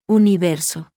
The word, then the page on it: u: Spanish